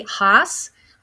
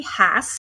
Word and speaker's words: Hass: Oftentimes people say Haas, but it's actually Hass.